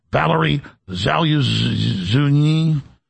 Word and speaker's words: Valery Zaluzhny: Valery Zaluzhny.